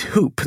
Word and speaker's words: Huupe: And last but not least, Hoop, that's spelled H-U-U-P-E, pronounced hoop like basketball hoop or alley-oop,